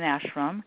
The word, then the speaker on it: ashram: So I know that you run, and I believe, correct my language here as well, is it pronounced ashram? Is that how you say that? – Ashram. Ashram. – Ashra? Okay. You know, ashram. – It's really funny. – Ashram with an M.